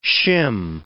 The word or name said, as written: shim